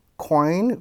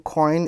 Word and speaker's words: Quoine: We have seen a recent case in Singapore, COIN versus B2C2. COIN is spelled Q-U-O-I-N-E versus B2C2,